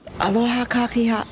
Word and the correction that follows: Aloha kakahiaka: Blah. Gotta pronounce it correctly. Aloha kakahiaka. That's good morning in Hawaiian.